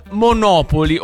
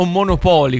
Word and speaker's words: Monopoly: Te ne posso dare una, intanto, che riguarda Netflix e il Monopoly, o Monopoly come in realtà dovrebbe essere chiamato, visto che l'accento dovrebbe essere là,